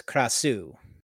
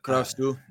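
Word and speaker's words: Krasue: You could go with the Thai pronunciation, which is Krasu. – Yeah, Krasu.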